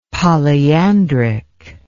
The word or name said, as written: polyandric